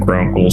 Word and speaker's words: Vgrunkael: I am, in fact, Grunkle, spelled V-G-R-U-N-K-A-E-L. The V is silent, apparently, I hear. Or don't hear.